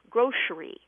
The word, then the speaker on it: grocery: Now, there aren't many new pronunciations newly acceptable, but one of them is grocery with a sh in the middle, grocery.